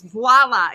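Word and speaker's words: voilà: I go, it's not voila with a V. It's voila. It's French.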